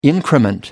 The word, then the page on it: increment: British English